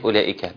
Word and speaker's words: أولئك: أنا شايف علامة على الواو بتاعت أولئك، بحب أسأل السؤال ده باستمرار، ما هذه العلامة؟ – دي من الألفات السبع، يعني هي لا تنطق، الواو لا تنطق. – جميل. هو مش حتة الألفات السبع، لأنها مش من الألفات السبع، إنما هذه العلامة على الواو تدل أن هذه الواو لا تنطق وصلا ولا وقفا.